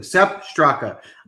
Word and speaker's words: Sepp Straka: But I'm going to stick with Strep Straka. Jeez, I can't say his name. Sepp Straka.